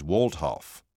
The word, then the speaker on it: Waldhoff: My first name is Dan, and my family name is Waldhoff. I'll spell that for you. It's W-A-L-D-H-O-F-F.